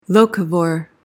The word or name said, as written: locavore